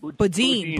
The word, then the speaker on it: Boudin: Ken uh, Ken Friedman, I want everybody to listen to whom uh, Chesa Bowden, am I saying his name right, or Bowden? Bowden mm. blames for his uh, recall. – What is Bowden? How do you say it? Boudin. Boudin. Whatever.